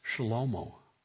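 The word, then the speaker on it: Shlomo (שלמה): Solomon, his name isn't pronounced that in Hebrew. It's pronounced Shlomo, which is where we get the word Shalom, which means peace.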